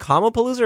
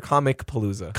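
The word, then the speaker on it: Comicpalooza: Is it Comapalooza or Comic Palooza? – Comic Palooza. – Oh, they put the hard C in there.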